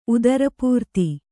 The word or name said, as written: ಉದರಪೂರ್ತಿ